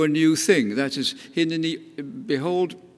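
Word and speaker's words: Hineni (הנני): Hinnaini in chapter 6 is pronounced slightly differently, and it's not stressed in the same way as in the other three cases. Hineni shlacheni, the stress is on send me.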